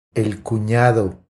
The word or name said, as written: El cunado